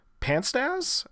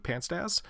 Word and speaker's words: Panstasz: Panstaz. I think that's how you say it. P-A-N-S-T-A-S-Z. Panstaz.